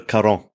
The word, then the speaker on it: Caron: Alexander Caron. The N is silent. They're kind of silent.